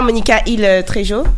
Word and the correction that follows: Trejo: Trejo. Ah, Trejo, peut-être. Trejo, ouais, ouais.